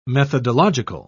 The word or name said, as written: methodological